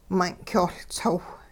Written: mun' k'yoh Ltsow

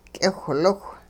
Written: k'iwhliwh

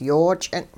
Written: yoo-ch'ing

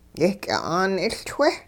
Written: yehky'a'aan 'iLchwe'

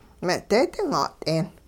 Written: me'deedin-'a't'een

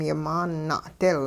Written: yimaan na'dil

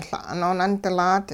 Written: La' noonundilaat

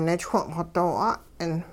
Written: nichwung-xw doo 'at'ing

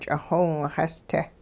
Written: ch'ixiwingxees-te

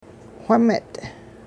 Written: whimet

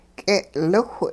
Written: k'e'liwh

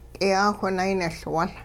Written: k'iyaawh-nayniLwul

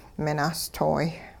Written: minahstooy

